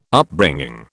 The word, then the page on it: Upbringing: British English